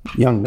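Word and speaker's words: Junge: And it's spelled J-U-N-G. J-U-N-G-E Makers. Young Makers. That's their family name.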